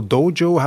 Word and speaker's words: dojo: 10 years later, you have your own Aikido school, Aikido dojo, how do you pronounce it? – Dojo, yeah.